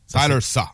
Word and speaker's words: Tyler Shaw: Tyler Shaw. – C'est comme ça que ça se prononce, là, mais c'est S-H-O-U-G-T.